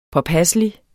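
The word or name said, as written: påpasselig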